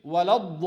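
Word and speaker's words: ولا الضالين: Same with waladdallin. On the lam, there is shadda as well. Many people you hear him saying waladdallin, especially the kids.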